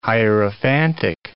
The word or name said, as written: hierophantic